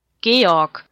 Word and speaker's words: Georg: Georg. It's actually pronounced Georg.